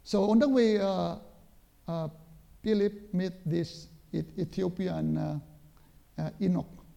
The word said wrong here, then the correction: eunuch: How do you pronounce it? Eunuch.